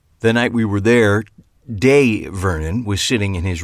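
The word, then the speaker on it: Dai: And it's Day. Excuse me? You said Di. People who knew him on the West Coast only pronounced it that way. But his true friends from the East Coast pronounced it Day.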